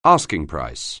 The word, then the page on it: asking price: anglais